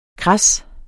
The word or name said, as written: KRADSET